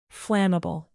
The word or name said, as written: flammable